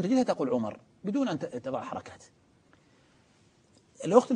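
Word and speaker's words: عمرو: نقول لأن عمر ممنوع من الصرف، والممنوع من الصرف لا ينون، فالذي ينون قطعا هو عمر. فالآن تبين أنه عمر ليس عمر، ما احتجنا إلى الواو، لأن هذه الواو ليست جزء من الكلمة ولا تنطق فيها، وإنما هي فقط جاء بها للتفريق اللفظي.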